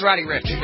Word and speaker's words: Ricch: Is that how you pronounce his name? R-I-C-C-H? Reach? Rich? – I think it's Roddy Ricch, but yeah.